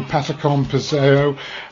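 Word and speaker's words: Patacón Pisao: The great man, Johnny Ventura, with what I'm calling Patacón Piseo, but John Bonfiglio probably can pronounce it slightly better than me. – No, I think it's good. I think since Chorizo, your pronunciation has been spot on.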